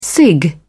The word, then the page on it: cig: British English